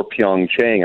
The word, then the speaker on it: Pyeongchang: Yeah, there was even a little bit of, sort of confusion even in recent months, whether it was going to be Pyeongchang or Pyeongchang. And I think the official American pronunciation is actually Pyeongchang.